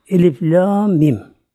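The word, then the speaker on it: Elif, la, mim (الم): Yani kesik olarak okunuyor. Birleştirilmiyor. Mesela elem denmiyor buna. Elif ayrı, lam ayrı, mim ayrı.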